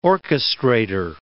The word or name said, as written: orchestrater